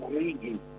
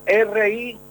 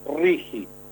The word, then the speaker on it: RIGI: Los porteños hablan del RIGI y está mal. Es RIGI. La G con la I se pronuncia como J. RIGI. Régimen de incentivo a las grandes inversiones.